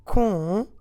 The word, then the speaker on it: contigo: So we had first comigo, nothing nasal about this, and now we have contigo, first syllable, nasal. But let's stress, as we always do in Portuguese as a general rule, let's stress the second to last syllable in both of them. So with me, comigo, and because the stress syllable is me, both O's go unstressed. And then contigo, then again the last O is also unstressed.